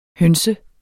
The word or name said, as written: høns